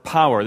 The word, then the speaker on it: power: This is a difficult word for me to pronounce correctly. For my part of the world, you say par. So please bear with me if I slip, but I'm going to try and say power and add a syllable for all of you.